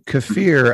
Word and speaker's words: kafir: So Abdurrahman Uh, kafir, as I read it, means someone who's a pagan, who doesn't believe in God. Am I saying that correctly? – Well, yes, and the, for Afghanistan, it has a deep pre-Islamic um, set of religious practices, particularly Buddhist.